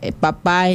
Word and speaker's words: pap: Pues le toca el turno al papá, que en la lengua yocotán se dice pap. Excelente. Pap. Vamos a deletearlo lentamente para que lo puedan escribir en casita. Papá en yocotán se dice pap.